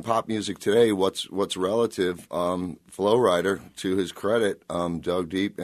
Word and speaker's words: Flo Rida: Flo Rida. Spelled Florida, pronounced Flo Rida.